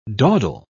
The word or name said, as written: dawdle